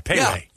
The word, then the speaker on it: Pei Wei: Yeah, it's spelled P-E-I-W-E-I. Payway.